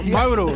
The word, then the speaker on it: Mauro: Mauro.